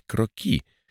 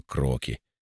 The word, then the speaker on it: кроки: «Значит, примерный чертеж местности правильно называть кроки», с ударением на последний слог. «А я всю жизнь считал, что кроки.